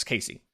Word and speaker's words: Caissie: I believe that's how you say it, it's Casey.